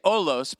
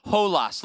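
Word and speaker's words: holos (ὅλος): Now, in the Greek language, the word all there is a word that looks like it should be, that should say olos, but it's actually pronounced holos. Look at your neighbor and say holos. Holos.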